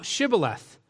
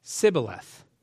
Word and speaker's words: Shibboleth: If he said nay, then said they unto him, say now Shibboleth. And he said, Sibboleth, for he could not frame to pronounce it right.